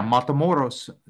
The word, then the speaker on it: Matamoros: Did I say it right? – Yeah, Matamoros. Matamoros. That's once you go, that is literally the crossing town when you go from Brownsville, Texas, you go into Matamoros, Mexico.